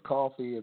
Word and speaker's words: Koffi: It's my name is Koffi. It's K-O-F-F-I, but it's pronounced like the stuff you get at Starbucks.